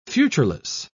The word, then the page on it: futureless: British English